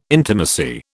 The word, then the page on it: intimacy: British English